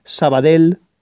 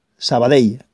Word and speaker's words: Sabadell: Así que no digáis más carbonel, no digáis más Sabadell, ni cosas parecidas. Carbonell, Sabadell, así es como se tiene que pronunciar.